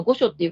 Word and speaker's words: 御所: 今回は奈良の御所市、京都御所の御所っていう漢字書いて御所って読みますが、ここにある千代酒造さんの篠峰純米生原酒薄濁りで合わせてみます。